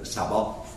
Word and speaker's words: Saba: Now back to the people of Shiva or Saba. – How is it pronounced properly? – Saba.